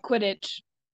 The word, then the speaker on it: Quidditch: Yep, Quidditch.